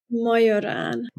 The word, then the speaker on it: Majorán: Is it Majorán or Majorán or? – It's Majorán.